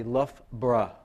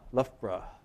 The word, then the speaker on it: Loughborough: If I could pronounce it correctly, I would say Loughborough. Loughborough. Okay.